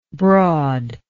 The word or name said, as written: broadish